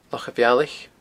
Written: Loch a' Bhealaich

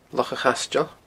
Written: Loch a' Chaisteil

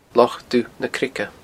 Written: Loch Dubh na Creige

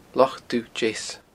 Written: Loch Dubh Deas

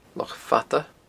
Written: Loch Fada

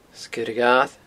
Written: Sgeir a' Ghair

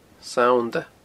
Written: Sannda

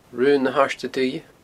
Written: Raon na h-Airde Duibhe

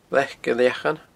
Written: Leac Gilleachainn

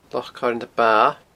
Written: Loch Coire na Ba

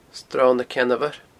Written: Sron na Ceann Mhoir

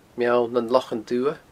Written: Meall nan Lochan Dubha

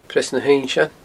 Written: Preas na h-Uinnsein

